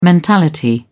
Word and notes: mentality: British English

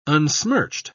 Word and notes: unsmirched: British English

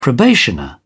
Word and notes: probationer: English; British English

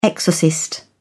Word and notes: exorcist: British English

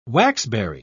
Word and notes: waxberry: British English

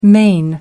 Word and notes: main: American English

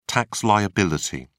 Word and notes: tax liability: British English

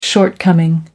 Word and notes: shortcoming: American English

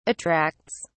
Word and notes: attracts: British English

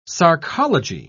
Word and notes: sarcology: British English

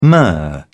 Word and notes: myrrh: British English

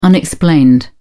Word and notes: unexplained: British English

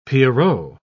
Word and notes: pierrot: British English

